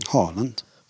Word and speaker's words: Haaland: Auf jeden Fall, der kann auf jeden Fall äh, den Namen richtig aussprechen. – Holland. Richtig.